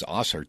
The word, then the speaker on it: Ausar: And then Detroit grabs Ausar Thompson, and it's spelled A-U-S-A-R.